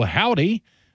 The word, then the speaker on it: Hovvdy: I'm your host, and up next, it's the Austin indie pop duo Howdy. Just to clarify, that is spelled H-O-V-V-D-Y if you want to look into these guys. This tune is called Gene. You discovered Howdy here on Notably Texan.